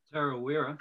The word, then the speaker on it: Tarawera: Tarawera. Did I say that right? – Tarawera.